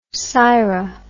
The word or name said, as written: syra